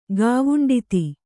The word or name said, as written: ಗಾವುಂಡಿತಿ